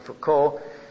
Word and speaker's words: Foucauld: His name is spelled F-O-U-C-A-U-L-D, Charles de Foucault.